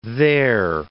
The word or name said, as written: their